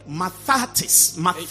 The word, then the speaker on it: mathetes: And that word disciple is from a Greek word, mathetes. It is spelled M-A-T-H-E-T-I-S.